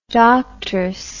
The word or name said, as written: doctress